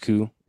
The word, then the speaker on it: Kyy: With it's a uh, uh, spelled K Y Y, but in Finnish that's pronounced Ku, and um, or Q, yeah, Q, something like that.